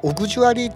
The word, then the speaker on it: AUX: オーディオコンポのですねちょうど裏にですね AUX ってあれオグジュアリーって読むんですけどすいません、オグジュアリーなんですけど、そのオグジュアリーにですね、 MD の MTR ね、これをですね、 MD の MTR を差し込むと。